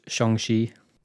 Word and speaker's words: Shang-Chi: It's pronounced Shang-Chi.